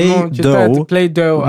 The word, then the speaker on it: Play-Doh: Вот, но пишется D-O-H именно название. – D-O-H? – Ну, название игрушки. – Да, да, да. – Play-Doh. Но читается как тесто.